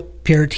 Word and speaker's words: Peart: I'll say it correctly. – There you go. – The lyrics were by Neil Peart.